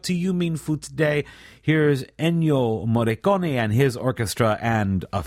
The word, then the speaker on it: Ennio Morricone: Ennio Morricone, or I should say, Ennio Morricone, that is the correct pronunciation, is, well, you'll know his music right away.